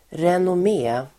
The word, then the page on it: renommé: [renom'e:]